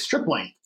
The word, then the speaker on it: Stripling: Can we say his name right? It's Stripling. Ross Stripling.